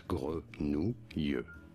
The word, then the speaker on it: grenouille: GRE. Then you have to separate the NOU and then I double L sounds like the letter Y and that'll make more sense. Grenouille.